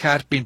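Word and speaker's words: Herpin: Ay, oiga, entonces, a ver, este señor Harpin, o no sé, ¿así se pronuncia? – Sí, pero él sí, Harpin, pero él nada más fue el que hizo la palabra filatelia.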